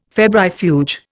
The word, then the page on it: febrifuge: Indian